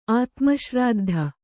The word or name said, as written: ಆತ್ಮಶ್ರಾದ್ಧ